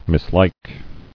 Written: Mislike

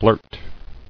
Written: Blurt

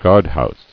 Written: Guardhouse

